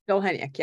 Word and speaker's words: Dolhaniuk: Dolhaniuk, yeah.